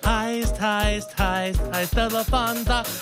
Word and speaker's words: Lufthansa: The Lufthansa Lufthansa heist is the biggest heist this in is U.S. The 821 news. We apologize for our earlier misstep. Uh, it is pronounced Lufthansa and uh, we apologize.